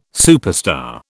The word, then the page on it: superstar: British English